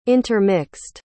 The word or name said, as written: intermixed